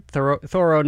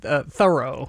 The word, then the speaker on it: Thoreau: So when Nathaniel Hawthorne first met him, Thoreau, in 1842, he wrote in his journal, "Dined with Mr. Thoreau," and he spelled it T-H-O-R-O-W. So he was spelling it phonetically. And then another time, Bronson Alcott, Louisa's dad, wrote that Henry is so well named, is well named because he is so Thoreau.